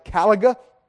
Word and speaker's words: caliga: Actually, the word for the shoes that Paul uses in this passage is, I believe you pronounce it caliga, C-A-L-I-G-A, not that it matters a whole lot, but it is a half boot, unlike these.